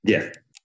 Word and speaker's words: GIF: Is it pronounced GIF or JIF? – GIF.